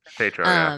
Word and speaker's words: Tatro: Tatro.